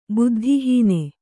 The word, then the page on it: ಬುದ್ಧಿಹೀನ: buddhi hīna